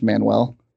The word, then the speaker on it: Manuel: Is that a play on words, like the manual? He's the playoff manual, like is in the manual you read, although his name is pronounced Manuel?